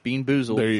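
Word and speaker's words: BeanBoozled: It's the Buckhorn Bamboozled Fiery Challenge. – You can't even say it right. – Buckhorn Bean Boozled Fiery Challenge. – Yeah. – Boon Beezled.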